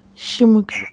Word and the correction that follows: симд: Нет, на конце Д, Дмитрий.